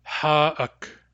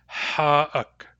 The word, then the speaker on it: Ha'ak: Ha'ak. Ha'ak. Be sure to pronounce the back H.